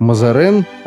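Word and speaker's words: Mazarin: По-французски ее произносили как Мазарен, а в русских переводах он стал и вовсе Мазарини.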